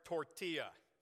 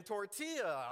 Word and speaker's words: tortilla: T-O-R-T-I-A? Sit down, white boy. Who knew that English has a double silent L in tortilla?